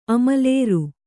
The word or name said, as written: ಅಮಲೇರು